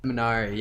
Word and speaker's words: Minari: And I'm not sure if it's pronounced Minari or Minari. – Minari.